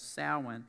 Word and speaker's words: Samhain: Now, if you look up the word Samhain, it is actually spelled like Samhain, the name Samhain, but it's called Samhain.